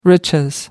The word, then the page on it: riches: American English